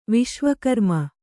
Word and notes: ವಿಶ್ವಕರ್ಮ: viśva karma

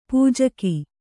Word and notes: ಪೂಜೆಗೈ: pūjegai

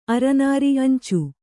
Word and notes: ಅಂಚೆ: Kannada

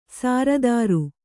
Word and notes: ಸಾರದಾರು: sāradāru

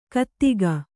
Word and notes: ಘಾತುಕ: kannada

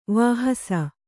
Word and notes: ವಹಿಸು: vahisu

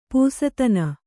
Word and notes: ಪಸದನ: pasadana

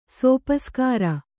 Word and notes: ಸೋಪಸ್ಕಾರ: sōpaskāra

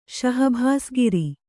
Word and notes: ಶಹಬಾಸುಗಿರಿ: śahabāsugiri

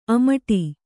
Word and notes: ಅಮಟಿ: amaṭi

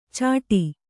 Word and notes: ಛಾಟಿ: kannada

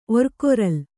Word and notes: ಒರ್ಕೊರಲ್: orkoral

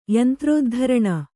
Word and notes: ಯಂತ್ರೋದ್ಧರಣ: yantrōddharaṇa

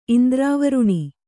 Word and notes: ಇಂದ್ರವಾರುಣಿ: indravāruṇi

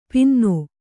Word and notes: ಪಿನ್ನೋ: Kannada

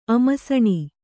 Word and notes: ಅಮ್ಸಾಣಿ: amsāṇi

